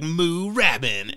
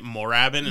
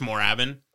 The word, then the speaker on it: Moorabbin: This is spelled Moorabbin. And for some reason, I love that word. When I saw it, I was like, Moorabbin. And I was like, that sounds amazing. And then I watched some videos and everyone was pronouncing it Moorabbin. And Moorabbin. I'm like, you're missing the opportunity with the 2 O's. – Yeah. – So it's just Moorabbin.